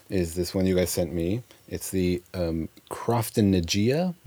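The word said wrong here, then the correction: Croftengea: Am I pronouncing it right? – Oh. – Crofton Gaea. – Yeah, Crofton Gaea.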